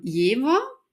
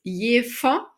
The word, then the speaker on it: Jever: Ja, wenn man so Touri hier ist, dann kann man das so aussprechen, wie es in der Werbung heißt. Jever mit einem W gesprochen. Und sobald man aber hierher zieht und sich einleben möchte und sich integrieren möchte, dann ist das Erste, was einem an den Kopf geknallt wird auf eine norddeutsche Art, dass das Jever heißt und dass das in der Werbung immer falsch ausgesprochen wird.